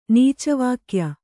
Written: ನೀಚವಾಕ್ಯ

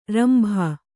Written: ರಂಬೆ

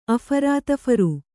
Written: ಅಫರಾತಫರು

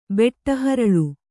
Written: ಬೆಟ್ಟಹರಳು

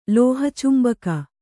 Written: ಲೋಹಚುಂಬಕ

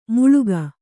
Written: ಮುಳುಗು

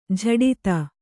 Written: ಜಡಿತ

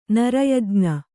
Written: ನರಯಜ್ಞ

